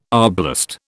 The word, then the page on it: arblast: British English